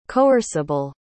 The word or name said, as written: coerceable